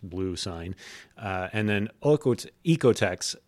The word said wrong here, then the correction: OEKO-TEX: I knew I was going to mess that up. Sorry. It's Oeko-TEX.